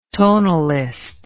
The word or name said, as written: tonalist